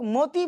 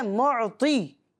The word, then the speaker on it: معطی: اس کو بعض لوگ موتی پڑھ رہے ہوتے ہیں، تو یہ موتی نہیں ہے، یہ لفظ کی غلطی ہے، یہ لہجے کی غلطی ہے، اس طرح نہیں پڑھنا ہے۔ اس کو پڑھنا ہے رب ہے معطی یہ ہیں قاسم، ٹھیک ہے؟